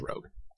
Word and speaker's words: Rogue: Or is it Rogue or Rouge? – Rogue. – I never know. – It's Rogue.